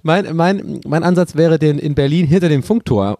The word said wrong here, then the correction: Funkturm: Funkturm so aufzustellen, dass einfach keiner so. Warum habe ich das so komisch ausgesprochen? – Funkturm.